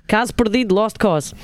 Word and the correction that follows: Caso perdido: No, he's not saying it right. Go ahead, Dan, say it right.